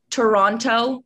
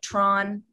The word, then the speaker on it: Toronto: I was born and raised in Toronto, which is not pronounced Toronto. You definitely, if you want to oot yourself as being not from there, you would say Toronto. But we actually say more like Tron.